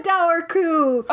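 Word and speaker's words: Dobharchú: I think that's how you say it. Dour coo. It's spelled D-O-B-H-A-R-C-H-U with a little accent ague on it. – It's dour coo. – Yeah, but it's dour coo. There's a B in it that you don't say because it's Ireland, so why not? – Silent B.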